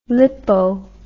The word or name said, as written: lipo